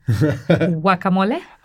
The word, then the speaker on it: guacamole: Guacamole. – Okay, see, everybody else, like me, guacamole, you call it, you don't pronounce the G.